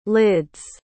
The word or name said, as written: lids